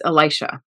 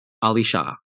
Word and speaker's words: Elisha: Which I went with it because in my entire upbringing, though, it was always Elisha. – Yep. – Like that was, well, that's how my pastors pronounced it. – Mm-hmm. – But it was kind of fun to have a way to say it. – Mm-hmm. – So for all of you out there that are wondering where in the world are we in the story, just imagine us saying Elisha and now you'll know, but it's pronounced Alisha.